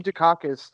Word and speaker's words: Dukakis: Olympia Dukakis? Is that how you pronounce it? – What? – Olympia Dukakis? – Dukakis, sorry.